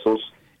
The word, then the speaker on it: SOS: Puis ton nom, ça se prononce bien Sauce, hein? – Ouais, exactement. Parfait. Sauce, S-O-S, Sauce. S-O-S.